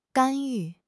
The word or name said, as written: 干预